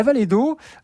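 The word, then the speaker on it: Vallée d'Aulps: La Vallée d'Eau, avec un nom qui s'écrit A-U-L-P-S.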